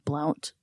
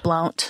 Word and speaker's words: Blount: Aaron Skeen told Maryville police officers that he had taken her to a place in Blount. Is it B-L-O-U-N-T? – Blount. – Blount. – Blount. Like Roy Blount.